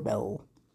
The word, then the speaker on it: Belle: Is it Belly or Belle? – Is it B-E-L-L-E? – Yeah. – Belle? – Belle. Okay, cool. Hate silent letters.